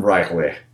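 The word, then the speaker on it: R'lyeh: There's a card game from Japan that's like Lovecraft theme called, it's very oddly spelled, so Fram, F-R-A-M, Rylech, the city under the sea in Lovecraft mythos. So Fram Rylech, however you pronounce that. – I think you got it.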